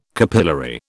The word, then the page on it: capillary: British English